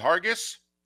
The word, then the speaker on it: Hargis: H-A-R-G-I-S. We going with Hargis? – Okay. – I'm going with a soft G sound instead of a hard G sound. You know, I'm going Colin Hargis.